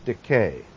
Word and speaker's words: Dike: And the Greek goddess of justice was Dike, D-I-K-A. Accent on the first syllable. And the A is long, Dike.